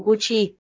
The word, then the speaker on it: Gucci: Từ chỉ là tên đọc sai của thương hiệu Gucci.